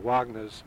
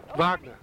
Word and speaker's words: Wagner: We just left a grotto, which was man-made underground after a scene from one of Wagner's... – Wagner! – I butchered that, didn't I? – You butchered that.